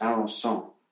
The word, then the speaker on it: Alençon: In, oh, pardon my uh, pronunciation, I believe it was pronounced Alençon, and uh, she was born and baptized there, and then they moved to Lisieux when she was still just a little child.